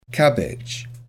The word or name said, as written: cabbage